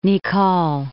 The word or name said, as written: Nicolle